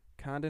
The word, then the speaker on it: Khand: Is that how you say it, Khand? – Yeah. – Khand and Harad.